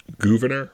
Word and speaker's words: Gouverneur: So he's just becoming boys with this guy called Gouverneur Morris, which... – Wait, say that again. – Gouverneur Morris, not governor. Just, Gouverneur.